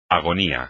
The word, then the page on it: agonía: espagnol